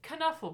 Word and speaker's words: Knuffle: And this one is inspired by an episode of a different podcast called Sawbones, where someone recently said, well, you pronounce the K of this word, like in Knuffle Bunny.